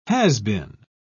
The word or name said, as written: has-been